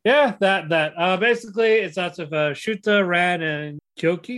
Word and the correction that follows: Koki: Is that how you pronounce his name? – Let me look at him. Chotomate. – The green hair ones. – Chotomate. Koki, yeah.